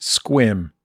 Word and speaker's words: Sequim: The E is silent. Oh. – It's effectively a one syllable word and is pronounced squim.